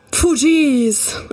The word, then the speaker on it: Fugees: Fugees.